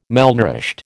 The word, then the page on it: malnourished: American English